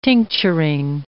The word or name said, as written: tincturing